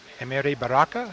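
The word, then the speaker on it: Amiri Baraka: Emery Baraka, is that how you pronounce mm-hmm. – it? – Yes.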